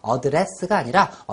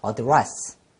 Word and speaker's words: address: 아 하면서 점차적으로 꼬부면 R 발음이 납니다. R 알겠죠? R 예, 그래서 앞으로 R 발음 나오면은 정확하게 꼬부려 가면서 그렇게 연습을 해야만 합니다. 처음부터 잘 되진 않아요. 계속해서 반복해서 그렇게 하려고 노력하는 과정에서 정확한 발음이 습득이 됩니다. 그래서 address가 아니라 address.